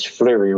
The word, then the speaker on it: Fliery: My email is Fleury Robinson.